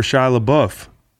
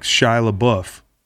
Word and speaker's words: Shia LaBeouf: Shia LaBeouf. – Taking a shot. – He's a dead ringer for Shia LaBeouf.